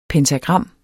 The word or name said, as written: pentagram